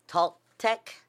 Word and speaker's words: Toltec: And I'm sure I'm pronouncing this wrong, Toltec, T-O-L-T-E-C? – No, that's right. – Okay, the Toltec and other Nahu people, N-A-H-U-A? – Not familiar with that one.